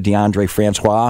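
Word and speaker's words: Deondre Francois: That really happened because in that Alabama game, remember DeAndre Francois? Am I saying that right? – Yeah.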